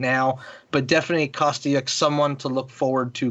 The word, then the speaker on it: Kostyuk: And we saw another one of the favorites to win, Elena Svitolina, stop another Ukrainian, which was in Kostyuk, I believe is how you pronounce it. – Yeah, Kostyuk.